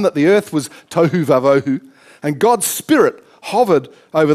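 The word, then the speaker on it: tohu wa-bohu: It's tohu vavohu. It's perhaps not spelt like that, but it's actually pronounced tohu vavohu.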